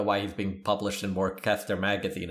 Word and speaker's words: Worcester: I want to say Worcester, but it's spelled Worcester. Just a C, no CH. – Oh, yeah, you're right. Orcester.